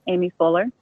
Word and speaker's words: Aimee Fuller: It's spelled A-I-M-E-E, Aimee Fuller.